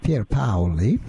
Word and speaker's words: Pier Paoli: Online does have some advantages, to the work of Dr. Walter, W-A-L-T-E-R. And then I'll spell the next one slowly. P-I-E-R. P-A-O-R. Paoli. Now, that's Italian for Peter Paul, but in Italian, it's pronounced Pier Paoli. So you put in Walter, P-I-E-R-P-A-O-L-I, and then you put in three capital letters, TRH, thyroid-releasing hormone, TRH.